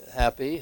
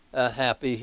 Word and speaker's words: Hapi: And the Nile deity here was, I think it's pronounced Happy here, H-A-P-I.